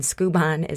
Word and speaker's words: Skubon: And Skubon is spelled S as in Sam,